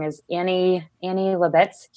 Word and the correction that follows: Leibovitz: huge, huge. Le- Leibovitz, yeah.